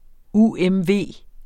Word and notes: UMV: ˈuˀˈεmˈveˀ; uεmˈveˀ

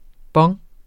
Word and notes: bong: ˈbʌŋ; ˈbʌŋː

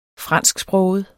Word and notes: fransksproget: -ˌsbʁɔːwəð; ˌsbʁɔˀwəð

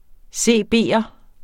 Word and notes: CB'er: ˈseˀˈbeˀʌ; seˈbeˀʌ

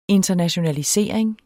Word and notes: internationalisering: [ ˈentʌnaɕonaliˌseˀɐ̯eŋ ]

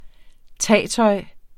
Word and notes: tagtøj: ˈtæˀ-; ˈta-

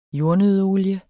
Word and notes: jordnøddeolie: ˈjoɐ̯nøðə-

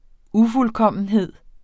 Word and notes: ufuldkommenhed: ˈufulˌkʌmˀənˌheðˀ; ufulˈkʌmˀənˌheðˀ

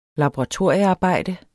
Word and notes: laboratoriearbejde: lɑboʁɑˈtoɐ̯ˀjə-; labo-

